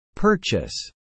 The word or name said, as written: purchase